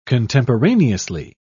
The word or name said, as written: contemporaneously